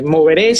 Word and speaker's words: Movere: Yo le comentaba o comentaba al principio que su organización, eh, no sé, me rectifica si lo dije mal, es Movere o Moveré. – Sí, bueno, como eh, Moveré siempre que le hemos dicho, pues este, Moveré nace, es una organización de...